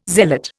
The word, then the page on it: zealot: Indian